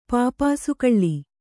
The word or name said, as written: ಪಾಪಾಸುಕಳ್ಳಿ